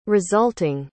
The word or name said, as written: resulting